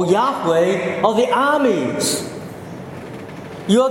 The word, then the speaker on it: Yahweh: It's spelled in capital, L-O-R-D. It's translating the covenant name of the Lord. We don't know how to pronounce that name, but we know the four consonants, the four-letter word. Holy word, the name of God, Y-H-W-H. Yahweh, perhaps. We're almost certain that it's not Jehovah in terms of pronunciation.